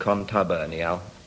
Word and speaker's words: contubernial: Well, yes, in fact, you pronounce it contubernial.